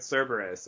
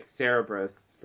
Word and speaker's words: Cerberus: I hate when that happens, but I think that happened with Cerberus. I think I was reading it Cerebrus for years, and I can't get it out of my head clearly.